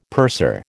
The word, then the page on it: purser: American English